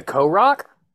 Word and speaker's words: Korok: Is he spitting a Korok? – No.